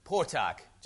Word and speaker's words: Potok: I think it's A-I-M. – Yeah. – Chaim Potok. – All right. Potok, just like it's spelled. – Yeah. – With a K.